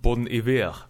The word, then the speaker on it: Bon Iver: Bon Iver.